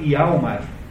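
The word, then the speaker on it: Hjalmar: E esse, e ao mesmo tempo esse Gregers tem um amigo de infância chamado Ialmar, né, escrito com J, eh, e nessas línguas eh, norte-europeias, o J pronuncia-se como se fosse I,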